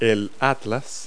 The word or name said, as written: atlas